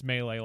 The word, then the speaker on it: Melee: I knew a kid in high school that would pronounce it that way, and I was like, no, dude, it's Melee.